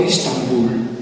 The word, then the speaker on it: Istanbul: Quando arrivano Maometto II gli ottomani, hanno già pronto il nome, lo pronunciano alla turca ed ecco Istanbul.